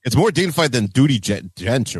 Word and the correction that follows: Judi Dench: I think that be careful of the pronunciation there. – Judi Dench, like it's that's not like you called her Judi Dench.